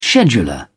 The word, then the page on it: scheduler: British English